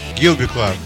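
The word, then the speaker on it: Gilby Clarke: Джилби Кларк или, или Гилби, как правильно? Гилби Кларк. Гилби, хорошо.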